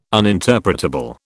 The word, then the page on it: uninterpretable: British English